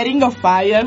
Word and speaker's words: Ring of Fire: Questo è Johnny Cash e Ring of Fire.